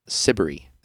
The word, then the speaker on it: Siberry: It is called The Speckless Sky, and it's by an artist named Jane Siberry, which rhymes with slippery.